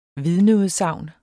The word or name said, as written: VIDNEUDSAGN